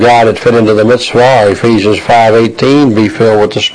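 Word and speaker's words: Mitzvah (מצוה): Mitzvah in the Hebrew. M-I-T-S-V-A-H. Pronounces mitz, M-I-T-S plus vah, V-A-W.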